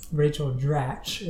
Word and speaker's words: Rachel Dratch: Rachel Dratch is how you pronounce her name.